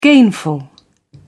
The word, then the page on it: gainful: British English